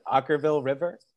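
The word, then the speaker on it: Okkervil River: We talked about funk, Ockerville River. Did I say it right? – You did.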